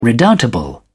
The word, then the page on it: redoubtable: British English